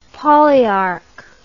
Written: polyarch

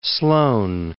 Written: Sloan

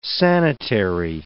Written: sanitary

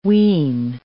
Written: wean

